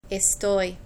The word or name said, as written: estoy